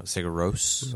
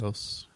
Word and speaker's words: Sigur Rós: By Sigur Rós. Yeah. No, Sigur Rós. – Rós. Say it right.